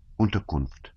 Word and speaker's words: Unterkunft: The German for shelter is Unterkunft. How do you say it? The captain asked quickly and doubtfully. Unterkunft, Pierre repeated.